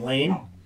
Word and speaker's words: Leen: Which is like, I know a guy named Lane, and it's spelled L-E-E-N. – Really? – But it's pronounced Lane.